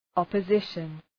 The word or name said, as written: opposition